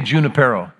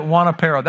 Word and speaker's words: Junipero: Am I pronouncing it in a suitable way for you? How many say Junipero? How many say Juanipero? We always said it Juanipero. That was a Long Beach way to say it.